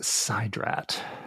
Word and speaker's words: Sidrat: I believe it's pronounced Sidrat. – Sidrat, like side quest that we're currently on.